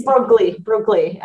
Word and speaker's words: Broglie: I think the way that you pronounce her name is Princesa de Broglie. And it's by Ankh. I know. – I would never be able to. – It's like spelled Broglie. It's Broglie in English.